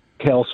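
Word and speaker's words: Kelce: I was the guy that drafted Kels first in the 16 team league. And Kudos to you, for committing to the Kels pronunciation.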